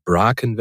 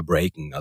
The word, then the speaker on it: Bracken: der ist nämlich Bracken wird da ausgesprochen. Und ich habe immer Breaken, also ich habe quasi so ein bisschen die amerikanische Version genommen und es sollte aber eher so das englische Bracken sein und ich habe Breaken ausgesprochen und die Autorin war halt dann not amused, wie man so sagt.